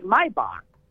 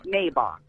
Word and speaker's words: Maybach: In Dutch, you would say Maybach, not Maybach. – Okay. – Maybach, huh? – So, okay. – So every time I hear that, I'm always like, people are pronouncing it wrong.